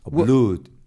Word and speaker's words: blood: Blood. – Well, it's blood. That's how we pronounce it. Like flood, inundación.